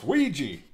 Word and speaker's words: Ouija: Ouija. It's pronounced Ouija. – Here on the other side, we pronounce it Ouija. – Because it's like squeegee. It's a Ouija.